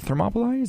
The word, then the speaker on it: Thermopylae: So Leonidas died at the Battle of Thermopylae. Is that my pronunciation? – Yeah, I think right? – That's right. – Yeah. Died at the Battle of Thermopylae,